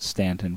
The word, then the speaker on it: Staunton: Next, if you're in the Stanton, Virginia area, our church is located at 1743 West Beverly Street in Staunton, is how it's spelled, S-T-A-U-N-T-O-N, Virginia. But here in the hills, we call it Stanton, Virginia.